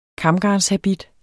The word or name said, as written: KAMGARNET